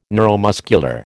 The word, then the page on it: neuromuscular: American English